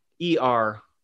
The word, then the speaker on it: Er: E-R.